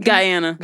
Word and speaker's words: Guyana: Wait, how do you pronounce it? I'm assuming because of the Spanish. – Guyana. Yeah, it's because we literally are written in Spanish. Guyana. That's how it sounds in Spanish.